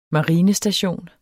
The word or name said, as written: marinestation